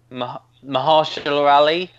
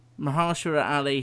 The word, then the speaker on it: Mahershala Ali: Mahershala Ali, I think. – Mahershala Ali.